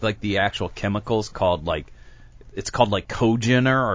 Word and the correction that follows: congeners: Congent? How would you pronounce it? Congeners? How would you pronounce that? That's the complex it picks up from wood. C-O-N-G-E-N-E-R-S. Cogeners? – Congeners.